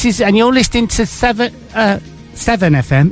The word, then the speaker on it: Severn: How he said Severn, he said it properly. – Hi, this is Derry from EMF, and you're listening to Severn FM. – Severn FM.